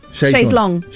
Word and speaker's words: Chaise Longue: How do you pronounce that? Chaise Longue. – Yeah. Chaise Longue. – Chaise Longue. – Isn't it? – Well, they pronounce it quite weirdly in the song, don't they? – Here are the lyrics.